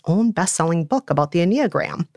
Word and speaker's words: Enneagram: And brief disclaimer, we came across many pronunciations of the word Enneagram, but we're pronouncing it Enneagram.